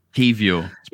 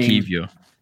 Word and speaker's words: Kiwior: There's no V in it. – Kivior. It's pronounced Kivior.